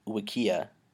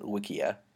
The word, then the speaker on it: Wikia: I just wanted to, as an aside, mention that you pronounced it Wikia, like, rhyming with IKEA. – Yeah. – Which, I pronounce it Wikia.